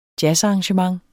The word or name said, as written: jazzarrangement